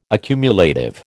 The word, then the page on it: accumulative: American English